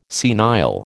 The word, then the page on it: senile: American English